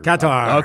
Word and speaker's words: Qatar: Or, Qatar.